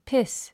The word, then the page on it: piss: pís; ピス